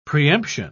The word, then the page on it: preemption: British English